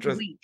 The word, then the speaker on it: Wheat: It's Shane Wheat. He basically says, think of the wheat, like the what you eat wheat flour. He says that's how you pronounce it, is wheat.